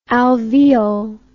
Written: alveol

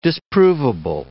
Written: disprovable

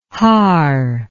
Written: haar

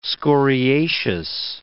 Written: scoriaceous

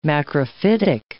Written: macrophytic

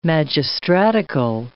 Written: magistratical

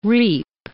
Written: reap

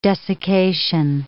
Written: desiccation

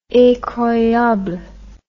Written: incroyable

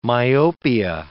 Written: myopia

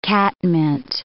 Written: catmint

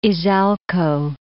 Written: Izalco

